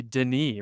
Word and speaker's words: Denis: You talked about Dennis and his, I believe with one N, the French pronunciation would be Denis, right?